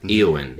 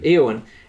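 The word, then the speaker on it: Éowyn: And he's hanging out with Eowyn? Am I saying that right? – Eowyn. – Eowyn.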